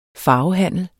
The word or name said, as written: farvehandel